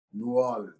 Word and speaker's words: New Orleans: Did I say it right? – New Orleans? – Yeah, you, you said you said it right. You gotta have that little that little draw, that twang with it. Yeah. Little, little laziness. You can't enunciate it properly, but just New Orleans.